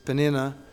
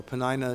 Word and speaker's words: Peninnah: And like it was with Rachel and Leah, Leah, and in this case, Penina, or Penina, as she's sometimes pronounced,